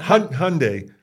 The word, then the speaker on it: Hyundai: Hyundai. – Yeah. – Like Sunday. – Let's go back to Hyundai.